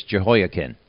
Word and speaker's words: Jehoiakim: Now this first question, I will not pronounce this name correctly. Tell me how to pronounce this gentleman's name ahead of time here. – Well, it's a Jewish king. His name is Jehoiakim.